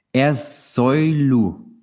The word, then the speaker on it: Ersoylu: Er Soilu.